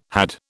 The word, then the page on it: had: British English